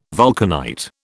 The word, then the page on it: vulcanite: British English